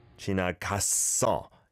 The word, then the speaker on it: 지나갔어: But you need to pronounce it 갔어 rather than 가서. Okay? Just nitpicking to give extra tips. – 폭풍 God. 폭풍 하루가 지나갔어. – 지나갔어. 갔어. The double shot sound is on that first syllable there. 갔어. And then blends over to the second one.